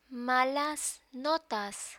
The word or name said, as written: malas notas